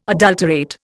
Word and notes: adulterate: Indian

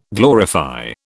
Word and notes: glorify: British English

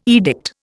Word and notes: edict: Indian

